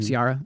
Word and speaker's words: Ciara: So the singer Sierra, mm-hmm. Ciara, I always say it wrong.